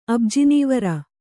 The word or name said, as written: ಅಬ್ಜಿನೀವರ